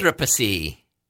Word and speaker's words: Anthropocene: Anthropocene. – That's how you pronounce it? – Anthropocene.